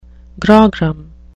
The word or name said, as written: grogram